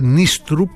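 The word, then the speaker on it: Nystrup: Όσον αφορά τον προπονητή του Παναθηναϊκού, προφέρεται Νίστρουπ γιατί έχει δύο «Η». Νέστρεπ, ο Λεωνίδας τον είπε Νέστρουπ νωρίτερα, ενώ Νίστρουπ τον άκουγα.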